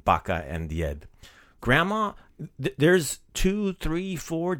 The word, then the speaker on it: Djed: But grandfather is Djed. – Djed. – So last episode, we learned how to say Santa Claus, which is Djed Mraz. So grandfather, that's the word for grandfather. Djed. – Djed. – That's spelled D-J-E-D.